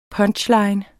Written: PUNCHLINE

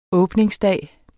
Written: åbningsdag

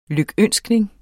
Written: lykønskning

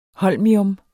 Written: holmium

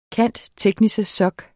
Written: cand.techn.soc.

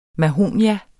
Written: mahonie

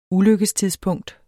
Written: ulykkestidspunkt

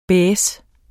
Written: baisse

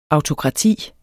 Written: autokrati

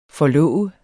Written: forlov

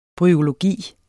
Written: bryologi

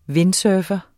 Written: windsurfer

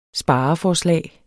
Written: spareforslag

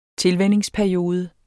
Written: tilvænningsperiode

